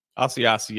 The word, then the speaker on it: Asiasi: and I think that felt like that Asiaki could, if that's how, help me there. Is that how you say it? – Asiasi, yes.